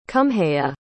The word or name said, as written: cumhea